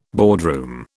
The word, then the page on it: boardroom: British English